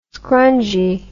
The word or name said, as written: scrungy